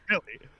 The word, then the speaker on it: Millie: And it's because today is Melly Bobby Brown's birthday. – Who? – Melly Bobby Brown's birthday. – Millie or Melly? – However you pronounce it. – Woo. Well, it's Millie.